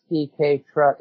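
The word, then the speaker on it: cestui que trust: Now, the S, you call it the SDK truck. Is it like the letter S and then DK? – No. Let me spell that out for you, because it's spelled differently than it sounds.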